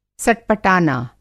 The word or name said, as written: सटपटाना